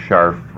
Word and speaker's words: Scharf: My name is Dennis Scharf. – How do you spell that? S-C-H-A-R-F? – Correct.